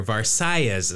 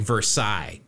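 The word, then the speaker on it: Versalles: Zoyo, who he's Cuban, so according to the pronunciation guide here, Versailles is how he pronounced it. It looks like Versailles if he was French, but he's not.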